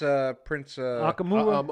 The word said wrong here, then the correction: Amukamara: How you pronounce that name, AC? – Amukamara.